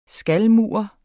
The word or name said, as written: skalmur